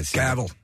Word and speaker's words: Cavill: How do you say it? – Cavill. – You say it correctly.